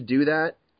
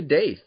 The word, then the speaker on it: Daith: it's actually pronounced Doth because da da da da da," I think people are going to be more annoyed at that. So I just let them say Daith, and you know, that's usually what I refer to it, because that's what it looks like it would be pronounced.